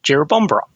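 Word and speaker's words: Jerrabomberra: So she said, Jirabombera is actually pronounced Jirabombera. It was instilled into me when I went for event 2.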